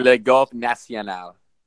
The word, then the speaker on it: Le Golf National: It's called Le Golf National. That's how they say it in French. – It's actually Le Golf National.